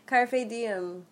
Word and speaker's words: carpe diem: Carpe blanc? Carte, what, how do you say it? Seize the day? – Carpe diem.